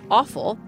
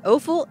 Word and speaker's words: offal: One quick note before she dives in, there's a debate in the food world about the pronunciation of offal. While you may have heard it pronounced awful, we're going to veer away from that pronunciation because, frankly, offal is anything but awful.